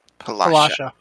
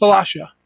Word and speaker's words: Palasha: Your girlfriend, Palagra? Is her name Palagra? – Palasha. – Okay. – It rhymes with Palasha. Your girlfriend Palasha is over there at the riverbank.